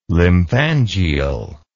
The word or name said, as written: lymphangial